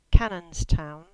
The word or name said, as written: Cannonstown